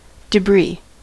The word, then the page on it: debris: American English; [də.ˈbɹi]